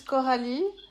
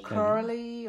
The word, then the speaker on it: Coralie: In French, it's Coralie. English is Coralie, or I don't know, Coco. Coralie. Yeah, it's tricky to pronounce in English. Like, it's Coralie. Coralie would be maybe the most...